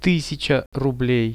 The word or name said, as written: 1000 rublei